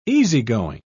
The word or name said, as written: easygoing